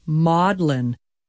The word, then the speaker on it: Magdalene: In Britain, Mary Magdalene's name is pronounced Maudlin. This contraction gives us the word maudlin, M-A-U-D-L-I-N, meaning sentimental and weepy, after the literary and artistic portrayals of Mary's personality.